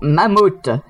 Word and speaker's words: mammouths: Aux mammouths. On ne prononce pas le S à la fin du mot mammouth, monsieur.